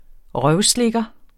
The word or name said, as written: røvslikker